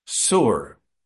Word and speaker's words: Tzur: Sur, T-Z-U-R, pronounced S-O-O-R.